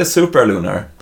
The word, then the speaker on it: Supralunar: Är det rätt uttal? Alltså jag vet inte. Jag säger Supralunar. De flesta säger Supralunar.